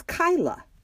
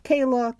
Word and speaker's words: Kyla: It's Kyla, K-Y-L-A. It's a beautiful Gaelic name, which means triumphant. Still, the author says her entire life she has been called Kayla, Kyle, Kylie.